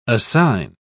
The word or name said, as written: assign